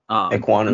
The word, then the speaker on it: Ekwonu: I think the pick is Ekwonu. I hope I pronounced that right. – Ekwonu. – I mean, Ekwonu, yeah.